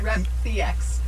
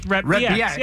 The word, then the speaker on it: Rep the X: Did I say that wrong? – Did you say Rep the X or DX? – Rep the X.